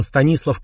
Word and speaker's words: Станислав: Вообще-то он не совсем Стасик, он Станислав Ковальский с ударением в имени на «и».